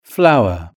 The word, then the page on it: flower: British English